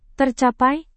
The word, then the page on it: tercapai: indonesian